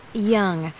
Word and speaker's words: Young: Young. Y-O-U-N-G.